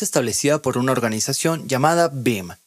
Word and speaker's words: BIEM: Para hacerlo más sencillo, se pronuncia BIM, y las siglas son B-I-E-M.